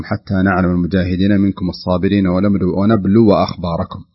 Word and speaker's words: ونبلو: هنا بعض الناس يقرأها ونبلو أخباركم، فلا بد أن ننتبه لمسألة الفتح الواو، فتح الواو فتصبح ونبلو أخباركم وليست ونبلو أخباركم. قد يقول قائل الألف الصغيرة التي بعد الواو مرسوم فوقها حرف مثل الحاء الصغيرة هذه لا تنطق، لا تنطق، تكتب ولكن لا تنطق، ونبلو أخباركم.